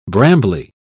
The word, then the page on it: brambly: British English